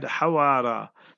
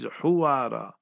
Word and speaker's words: Huwara: You see the Western press, they write Hawara. They in the West Bank. The word in Arabic is Huwara.